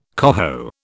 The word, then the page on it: coho: British English